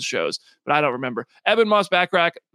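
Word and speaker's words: Ebon Moss-Bachrach: So we have the cast, and we now know how to say their names. Eben Moss Backrack. I think I saw somebody somewhere, like on a very public forum, I forget what it was, called him Ebon, and they were like instantly corrected to Eben on the show I was watching.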